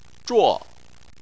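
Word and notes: zhuo: tone 4

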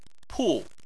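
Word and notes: pu: tone 4

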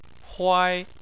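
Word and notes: huai: tone 1

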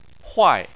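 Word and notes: huai: tone 4

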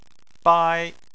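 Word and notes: bai: tone 1

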